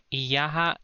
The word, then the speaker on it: iyaha: Again, iyaha is in prepos form and niya is in postpos form. So niya is spelled NIYA, niya. Iyaha is spelled IYAHA, iyaha.